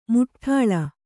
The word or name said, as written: ಮುಠ್ಠಾಳ